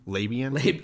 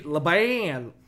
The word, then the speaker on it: Laban: Labian? – Laban.